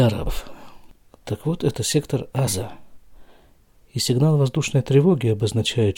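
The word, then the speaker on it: Аза (עזה): Что из сектора Азы, это именно так на иврите произносится, Аза, не Газа.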